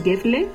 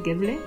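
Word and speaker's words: Gävle: pero creo que es Gefle, se escribe como Gafle a con diéresis, pero creo que es Gefle,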